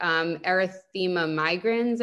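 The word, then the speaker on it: erythema migrans: Like I started getting that erythema migraines, I think. Is that how you say it? Yes.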